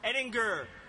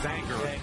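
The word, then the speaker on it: Ehinger: 105th pick of the 2016 NFL Draft, the Kansas City Chiefs select Parker Ehtinger, guard, Cincinnati. – Actually, it's pronounced Anger, Parker Anger, 6'6", 310.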